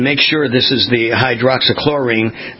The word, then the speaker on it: hydroxychloroquine: Hydroxychloroquine, yes.